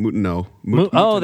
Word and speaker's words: Moutinot: Oh, I think the T is silent. Moutinot.